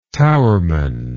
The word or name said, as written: towerman